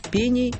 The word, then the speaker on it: пени: Вы не сделаете ошибку в ударении, если, склоняя слово «пени», будете мысленно ориентироваться на слово «штраф». «Штрафа» – «пеней», «штрафу» – «пеням». В обоих словах ударение всегда на первом слоге.